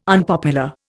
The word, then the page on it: unpopular: Indian